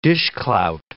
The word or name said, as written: dishclout